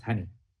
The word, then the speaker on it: Honi: There was one of my first beekeepers, an amazing guy called Pumlani Horni, H-O-N-I, which is actually pronounced honey.